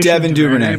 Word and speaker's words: Devin Duvernay: Have we said on the pronunciation? – Devin Duvernay. Yep, Devin Duvernay.